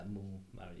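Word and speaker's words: moules marinière: Yeah, mulls marinara. You don't pronounce the S.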